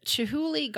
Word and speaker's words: Chihuly: The Chihuly Garden and Glass.